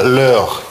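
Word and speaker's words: leur: И «их» – это «лёр». Независимо от числа читается одинаково.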